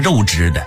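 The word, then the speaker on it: 月氏: 当时匈奴西边还有一个国家月支，据说月支拥兵十万，兵马强壮，实力不容小觑。这个月支啊，就是月亮的月，支呢是姓氏的氏，在这里国家读成月支。在早的时候呢，也有把它读成肉汁的，说明这个月也是个多音字，或者是呢是一个假借字。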